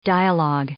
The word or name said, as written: dialogue